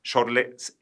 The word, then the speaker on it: Schorle: Se escribe S-C-H-O-R-L-E. La C, digamos, es muda. Es como si fuera Chorlé S-H. Así claro. Se pronuncia.